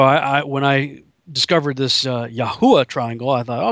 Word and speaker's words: Yahuwah: And it was always pronounced Yahu. So that's why I called it the Yahuwah Triangles. That's why I settled on the pronunciation of Yahuwah. But it also, just to be frank, rhymed with Bermuda.